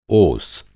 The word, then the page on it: ओस: اوس